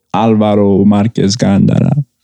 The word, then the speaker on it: Alvaro Marquez Gandara: Alvaro Marquez Gandara.